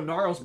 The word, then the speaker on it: Gnarls: We got Guinness Barkley. Is that how you say his name? Crazy. – I agree. Like, you Charles Barkley? Oh, Gnarls Barkley.